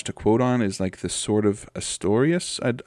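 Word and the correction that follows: Artorias: I don't know if I'm pronouncing that right. The one that the wolf wields in Dark Souls. – Artorius from Dark Souls. – Sword of Artorius.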